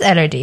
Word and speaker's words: Elordi: I sound really rude not knowing how to pronounce people's names, but it is Elordi, yeah? – Yeah.